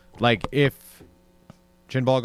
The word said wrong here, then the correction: Chibnall: goes, or what's Chinball? What, how do you say it? – Chibnall. – Chibnall.